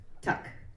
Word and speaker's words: Tuch: Did I say it right, Tuch? – Tuck. – Tuck? – Yeah. – Oh, 20 years I had it wrong.